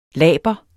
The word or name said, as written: laber